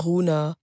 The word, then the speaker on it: Rounhaa: Entweder heisst er äh, Runa oder Rona oder Runa. – Ich glaube, man sagt Runa.